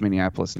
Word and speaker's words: Minneapolis: I learned how to pronounce Minneapolis now. I'm not going to put the Indianapolis in it like I used to with a few extra syllables.